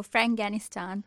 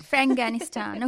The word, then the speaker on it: Franganistan: This year, she's focusing on a one-person show, Franganistan. Did I say that right? – You did. – Franganistan. Okay.